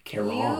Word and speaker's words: Charon: Pluto's moon's name is Karen? – Charon. – You might be able to say... – Does Pluto want to speak to the manager? – Well, this is how you pronounce... You could say Charon. – Charon. Sharon! – Sharon. It's either Sharon or Karen.